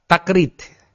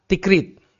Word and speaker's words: Takrit: Nah, Takrit ini kata al-Imam Yaqut al-Hamawi dalam Mu'jamul Buldan, ya, dalam Mu'jamul Buldan beliau menyebutkan cara membaca kota Takrit yang benar, yakni bifathil ta, ya, bifathil ta. Jadi ta-nya itu di fathah, Takrit. Sebenarnya namanya itu adalah kota Takrit. Wal ammatu yuksirunaha, ya. Adapun orang-orang awam, ya, masyarakat umum membacanya dengan dikasrah.